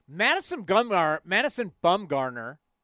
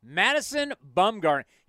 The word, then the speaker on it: Madison Bumgarner: Madison Bumgarner. God, I butchered that. Madison Bumgarner. He's not 6'7".